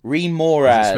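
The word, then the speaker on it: Remorhaz: Remoraz. – Smurf Neblin. Oh, Remoraz, okay.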